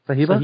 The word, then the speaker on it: Sahiba: We can go with, I don't even know how to pronounce this, S-A-H-I-B-A. – Sahiba? – Sahiba? – Sahiba, yep.